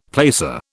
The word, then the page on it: placer: British English